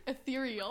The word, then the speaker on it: ethereal: Wait, how do you say it? – Ethereal.